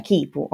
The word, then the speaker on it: quipu: quest'ultimo è lo, lo, spelling, la scrittura in spagnolo e, e si pronuncia Kipu, ok? Eh, l'ho sentito nominare Kipu, Kuipu.